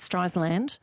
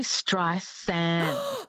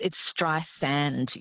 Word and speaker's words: Streisand: It's actually Streisand, like sand on the beach. – I'm not pronouncing it. Oh, my God. I'm under the desk. – I know. Look, everyone says Streisand with a Z, but it's Streisand. Yes, she's very specific about that.